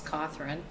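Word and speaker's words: Cothran: Well, I should start with when I was cannery worker, my maiden name was Cawthorn, so I was Becky Cawthorn to most people I worked with.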